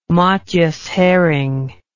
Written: matjes herring

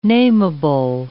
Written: namable

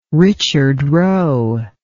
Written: richard roe

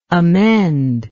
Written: amender

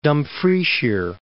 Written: Dumfriesshire

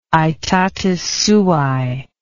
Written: aetatis suae

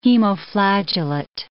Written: hemoflagellate

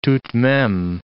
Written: tout de meme

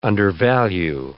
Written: undervalue

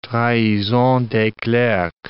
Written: trahison des clercs

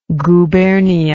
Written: guberniya

